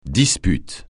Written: dispute

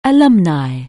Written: alumnus

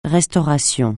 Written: restauration

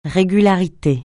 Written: régularité

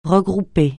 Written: regrouper

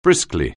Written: briskly

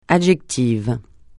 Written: adjectif

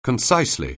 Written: concisely

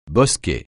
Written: bosquet

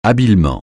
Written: habilement

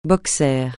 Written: boxer

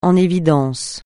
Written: en évidence

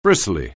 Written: bristly

